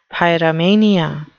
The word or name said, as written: pyromania